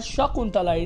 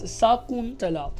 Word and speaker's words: Shakuntala: So that's a K, you know, with a double O, and not a K with a U. So it's not Shakuntala, it's Shakuntala. The U is pronounced like the U in the English word rule.